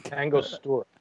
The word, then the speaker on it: Angostura: But yeah, and how do you pronounce that? The Agonostra Angostura. – Angostura.